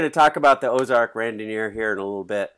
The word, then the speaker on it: Randonneur: And we're here to talk about something that's happening this fall, the Ozark Randonneur.